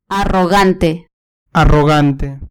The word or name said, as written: arrogante